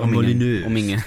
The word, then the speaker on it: Molyneux: Det är bara det att jag har all, alltid undrat hur fan karn ska uttala sitt namn. Molinje. Ja, Peter Molinje. Ja, bra. Exakt.